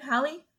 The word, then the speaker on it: Halle: Halle? – Yeah.